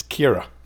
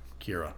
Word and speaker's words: Ciara: So even our daughter's name is C-I-A-R-A. And I would say Ciara. – Yeah, and Americans never get it. It's Kira. – Kira. – It's just two syllables. So it would be K-I-R-A if you were to do it phonetically, I suppose. – Yeah. – Or K-E-R-A.